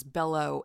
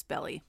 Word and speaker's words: Altobelli: I thought it was Bello at the end. It's Belly.